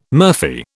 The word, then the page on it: murphy: British English